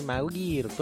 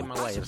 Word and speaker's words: Maguire: Bueno, hoy tenemos el cumpleaños del actor estadounidense Tobey Maguire, el actor de Spider-Man de la primera. – Sí. – No sé el apellido, cómo se pronuncia bien. – Maguire. – Yo diría Maguire. Sí.